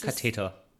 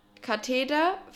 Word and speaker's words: Katheder: Nicht Katheter. Katheter kriegst du. Mit, in, nee, mit D. Doch, schwöre, das heißt so. – Okay, jetzt äh, erstmal Beef. – Ja, es schreibt man mit D. – Kathedra. Wie Kathedrale.